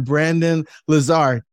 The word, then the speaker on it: Brandon Lazar: We're up in Canada with my man, Brandon Lazar.